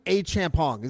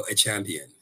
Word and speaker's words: Achampong: I do Achampong. Is that how you do it? – You're doing good. I mean, look, I love how CTV pronounces it. They always say Rocco Achampion. It's like, sure, whatever. Whatever.